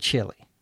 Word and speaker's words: Chile: And chili, C-H-I-L-I, is a food that you can eat. You can eat chili in Chile. We used to say stuff like that when we were kids. Same pronunciation, three different spellings. Isn't English wonderful? The radio announcer continues that the skies will be overcast. Overcast, O-V-E-R-C-A-S-T, means there are lots of clouds in the sky, usually gray clouds.